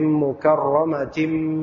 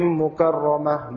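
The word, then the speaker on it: mukarramatin (مكرمة): Maka nanti kalau antum mau nyambung wasal dari ayat 13 ke 14 bacanya fi suhufim mukarramatin marfu'atin mutahharah. Ya. Jangan baca fi suhufim mukarramah marfu'atin mutahharah bi aidi sa'a, itu bukan wasal namanya.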